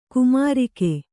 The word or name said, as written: ಕಾಮರಾಗ